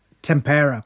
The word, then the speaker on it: tempera: I was experimenting with tempura. Am I saying that right? – Tempera. – Tempera. Tempura is the deep fried vegetables, isn't it? – Yep.